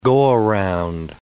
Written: go-around

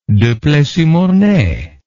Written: Duplessis-Mornay

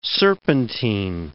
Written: serpentine

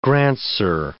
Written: grandsir

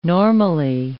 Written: normally

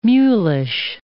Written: mulish

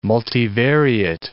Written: multivariate

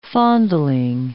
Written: fondling

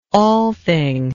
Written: althing

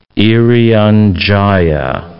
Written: Irian Jaya

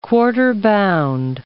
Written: quarter-bound

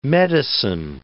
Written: medicine

